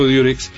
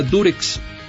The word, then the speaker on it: Durex: la marca Durex. Así se llama Durex, no Durex. Lo que pasa es que nosotros pronunciamos, al decir Durex, uh-huh. Pronunciamos la U como se pronuncia en inglés, U, okay.